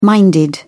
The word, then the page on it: minded: British English